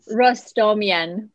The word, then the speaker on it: Rostamyan: Rostamyan.